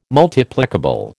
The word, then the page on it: Multiplicable: American English